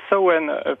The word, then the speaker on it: Samhain: Mais en fait, traditionnellement, en fait, au départ, cette fête, en fait, on dit pas Samhain, on dit plutôt Samhain, parce que en irlandais, ça se prononce pas du tout comme ça s'écrit.